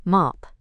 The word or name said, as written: mop